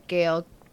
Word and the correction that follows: Gal: Gal Gadot.